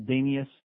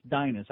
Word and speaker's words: Dinus: By the way, Geldy, it's Dinus Zubris, okay, not Danius. I don't want to nitpick with you because I know you pretend you know something about hockey. It's Dinus. I know it looks like Danius, but it's pronounced Dinus Zubris.